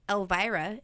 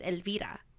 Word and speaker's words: Elvira: So my name is Elvira in English, and in Spanish it's Elvira.